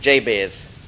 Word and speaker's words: Jabez: His name is Jabez. It wouldn't be pronounced that way in Hebrew. But anyway, we'll give it the English pronunciation, Jabez.